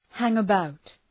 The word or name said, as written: hang about